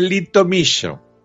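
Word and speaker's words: Litomyšl: Nos vamos a la ciudad de Litomyshl. Y esto te lo he pronunciado bien porque antes en el reportaje no lo hemos pronunciado bien.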